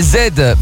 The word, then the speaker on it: Zedd: Et il y a Funky Man qui dit: c'est pas Zid, c'est Z. Ben si, ça se dit Zid parce que le E en anglais ça se prononce I, donc c'est Z-E-D-D, mais ça se prononce Zid avec Hayley Williams.